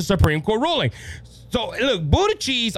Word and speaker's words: Buttigieg: It's even come to a point where Buttigieg, yes, I said it right, Buttigieg,